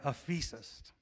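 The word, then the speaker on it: aphesis (ἄφεσις): If we spell it in English letters, it's A-P-H-E-S-I-S, aphesis. And the verb is aphemi, to forgive. Forgiveness, to forgive, aphesis, aphemi. He looked at him and he said, your sins are aphesis.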